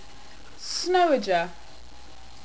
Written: Snowager

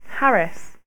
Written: harris

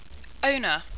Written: Ona

